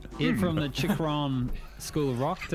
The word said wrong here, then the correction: Chiron: Chikron? No, Chiron. – Chiron? – Chiron.